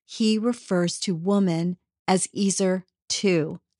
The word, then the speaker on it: ezer: Now, the English word helper or helpmeet in Genesis 2:18 is translated from the Hebrew word ezer. Some people pronounce it azer. Doesn't matter. E Z E R.